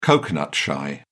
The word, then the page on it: coconut shy: British English